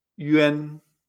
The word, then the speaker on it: yuan: Ya no se pronuncia esa U como una U, se pronuncia Y y entonces ya no se pronuncia one, sino que se pronuncia Yuan. ¿eh?